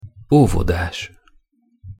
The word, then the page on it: ovodas: Hungarian